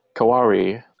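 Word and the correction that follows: Kaworu: No, Kawaru, okay.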